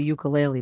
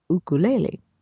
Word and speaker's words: ukulele: He plays the, what I thought was a ukulele, but it's actually pronounced ukulele.